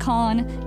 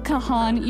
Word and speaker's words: Kahan: The artist's name is Noah Kahn, and his last name is one syllable. It's Noah Kahn, just Kahn, not Kahan, even though it's spelled K-A-H-A-N.